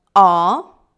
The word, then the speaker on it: ho: Io ho, tu hai, lui ha, lei ha, lei ha, noi abbiamo, voi avete, loro hanno. Sentito? In italiano la H si scrive ma non si pronuncia.